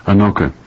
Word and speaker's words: Anoka: Is it pronounced Anoka? – Anoka, yeah.